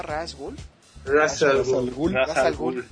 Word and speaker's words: Ra's al Ghul: ¿cómo se pronunciaba Ra's al Ghul? – Ra's al Ghul.